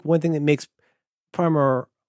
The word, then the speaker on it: Primer: The person who made the movie, the aforementioned terrible person, gave up on trying to get people to say Primer. So now officially it is called Primer because that's what everybody was calling it, which is a bummer for pronunciation, but it is what it is.